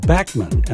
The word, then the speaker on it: Bachman: Randy Bachman is pronounced Backman, and I heard that from the man's mouth just the other day on a video.